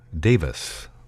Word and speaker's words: Davies: Davis. It's spelled like Davies, but it's pronounced Davis, who was born on the state 92 years ago.